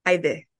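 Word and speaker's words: Haydée: We had quite the intro, as I was bound and determined to pronounce your name the way it's originally supposed to be pronounced and not the Americanized version. And it's Aide.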